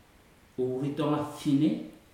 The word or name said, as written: uritoga fine